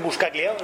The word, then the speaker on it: Buscaglia: Bueno, aquí estamos con Kiko Veneno y Martín Buscaglia, ¿lo pronuncio bien? – Sí, perfecto.